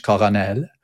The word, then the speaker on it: colonel: The pronunciation of colonel comes from the French, colonel, which you can see written down,